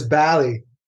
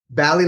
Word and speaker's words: Balli: It is BALLI. BALLI like Cali.